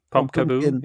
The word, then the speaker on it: Pumpkaboo: Pumpkaboo, zeg ik dat goed? – Pumpkaboo, ja, van Pumpkin en...